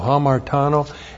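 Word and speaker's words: hamartano (ἁμαρτάνω): H-A-M-A-R-T-A-N, long O, hamartano.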